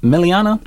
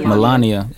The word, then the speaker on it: Melania: Is it Meliana? – Melania.